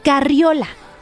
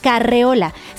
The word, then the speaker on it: carriola: Sin embargo, la forma correcta es carriola, con esa I que muchas veces se nos olvida. ¡Wow! ¿Qué? ¿Cómo que con la I? ¿Sí? Así se escribe. Es curioso porque esta palabra la usamos casi a diario cuando hay un bebé en casa o aún así es de las que más se presta confusión en la escritura. Pero no, no se dice ni se escribe carreola. Se escribe carriola, con todo y la I, con la I latina.